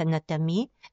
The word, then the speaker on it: натами: Трябва да ви обясня, че думичката над, а в женски род се произнася натами, с ударение върху и, над или натами, това са бирманските духове покровители на домашното огнище, на селото, на природните стихии, явления и обекти.